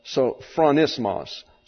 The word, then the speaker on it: sophronismos: And we have let's see, I have to get my accent on the last syllable, which is not normal in English. We have sophronismos.